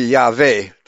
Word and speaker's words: Yahweh: The name was pronounced Yahweh. It was all in German, would be Yahweh. The W is pronounced like a V, as it was in Hebrew.